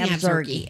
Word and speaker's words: Absarokee: I can never pronounce this right, Evzarki. Is that where they're playing? Or no, they're playing. – They're playing Absarokee.